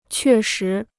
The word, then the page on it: 确实: què shí